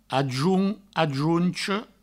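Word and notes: ajonch, ajoncha: ajount, ajouncho

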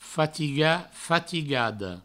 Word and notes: fatigat, fatigada: Provençal; fatiga, fatigado

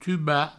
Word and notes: tubar: tuba

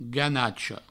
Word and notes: ganacha: ganacho

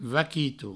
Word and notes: vaquito: vaquitou

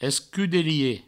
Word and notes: escudelier: provençal; escudelie

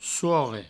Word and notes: sorre: souorre

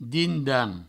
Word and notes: din-dan: Provençal

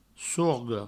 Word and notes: sorga: sorgo

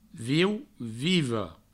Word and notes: viu: viva; vieu; vivo

